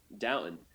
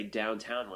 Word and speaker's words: Downton: And I said, "Downton." And he said, "I can't tell you how many people say downtown when they come here."